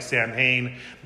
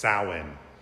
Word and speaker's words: Samhain: It looks like Samhain, but in Gaelic it's pronounced Samhain.